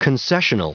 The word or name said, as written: concessional